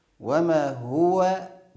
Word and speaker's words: وما هو: وما هو ضم الهاء، بعض الناس ينطقها بالكسر، وما هو وما هو وما هو على الغيب، لا نقول على الغيب على الغ، هذا من الأخطاء.